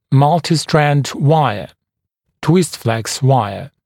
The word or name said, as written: multistrand wire twistflex wire